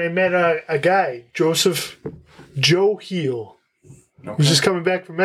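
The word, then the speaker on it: Hiel: I want to make sure I get his name right because it's H-I-E-L is his last name. Joe Heel. – Heel. – Yeah.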